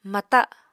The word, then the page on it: mata: Apatani